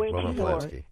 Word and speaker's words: Roman Polanski: From people as diverse as Roman Polanski, that's probably the incorrect pronunciation, who raped a 13-year-old. – Yes, that's right, Roman Polanski.